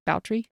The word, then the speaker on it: Bathory: Yeah, it's Elizabeth Bathory, but pronounced Bowtry.